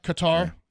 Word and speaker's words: Qatar: It's like guitar, Qatar.